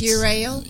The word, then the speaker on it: Ural: Hey, you said it. – I did say it right. Yeah. It's Ural? – It's Ural. – Ural. – Yeah. Yeah. – Okay. – The only way I could remember it is, you know, I'm a rail, you're rail.